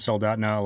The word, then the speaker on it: Soldotna: And you're located in, you mentioned Soldotna, Alaska.